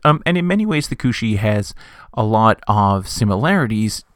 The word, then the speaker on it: Cusith: Our first animal is the Kushi. Now here that's spelled C-O-O-S-H-E-E, which I think is an older kind of anglicization of the spelling. You're more commonly going to find this spelled C-U-S-I-T-H.